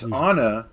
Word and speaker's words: Auna: It's Anna, but it's spelled Auna. A-U-N-A.